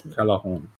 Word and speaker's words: Callejón: Callejón. – Ah, thank you. – The J is pronounced as the H.